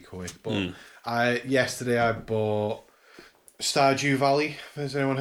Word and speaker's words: Stardew Valley: It's called Stardew Valley. – Okay. – As in with a D. It's S-T-A-R-D-E.